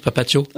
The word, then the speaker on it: Papatio: C'est comme ça que ça se prononce, Papatio.